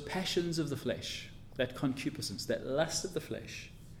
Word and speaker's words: concupiscence: Concupiscence. I'll spell it for you. C-O-N-C-U-P-I-Sense. S-C-E-N-C-E. Concupiscence.